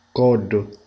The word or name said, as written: collo